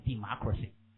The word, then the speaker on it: democracy: 念成 democracy right 啊念 democracy 好